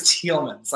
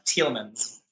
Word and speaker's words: Telesman: Our last name, it's pronounced Telesman's. – Like Telesman's like the color. Okay, got it. – Yeah, like the color, men's, or man's, if you wish. Yeah, Telesman. The S is on the end.